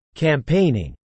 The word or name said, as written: campaigning